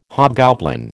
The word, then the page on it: hobgoblin: American English